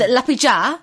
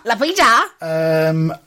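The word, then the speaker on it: Liepāja: And the point that me and you both got was for Lipia versus Tuckums. – Let me pronounce that properly for you. It's Lapija versus Tuckums. – Tuckums! – Tuckums! – Tuckums! – 5-0 to Lipija. – Lapija.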